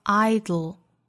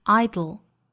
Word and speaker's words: idle: 这个词呢，读成 idle，舌尖抵住上齿龈，舌头两侧爆破送气，idle。而美音更多会发成 idle，也就是弹舌音加舌侧音的组合。